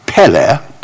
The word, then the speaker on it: pele: That's the Hebrew P. That's the short E. That's their L. That's a short E, and that's an ah sound, the aleph, an ah sound. Pele, pele. Wonderful.